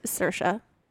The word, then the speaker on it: Saoirse: And then Swarcy? – Did you imagine that? Let me ask you. – Swarcy? – No. – How do you Saoirse. – Say it? Is that how you say it? – That is Saoirse.